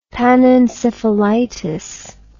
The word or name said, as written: panencephalitis